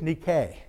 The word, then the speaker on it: Nike: By the way, that's actually pronounced in the Greek is Nike.